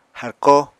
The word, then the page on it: harkó: apatani